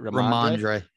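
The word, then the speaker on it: Rhamondre: I noticed that last bullet you had, and I've been loving watching this guy, Ramondre. Is that how you say it? – Ramondre. Yep.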